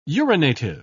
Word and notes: urinative: British English

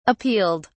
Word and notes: appealed: British English

